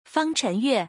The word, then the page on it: 方宸乐: Fang Chenle